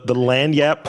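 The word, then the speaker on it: lagniappe: The land yap portion of the podcast. – That's right. You said it right.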